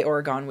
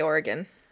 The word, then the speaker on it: Oregon: I think I say Oregon weird. Is it, how do you say it? Oregon? Oregon? – I say Oregon.